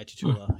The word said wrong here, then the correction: Chiwetel: Yeah, or I can never say his name properly, but Ejiofor. – Chiwetel Ejiofor.